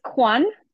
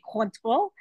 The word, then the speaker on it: Cointreau: Is it Cointreau? – It's Quan, like Kwanzaa, I guess is okay. Like the most American. I mean, we could go really French, we say Cointreau.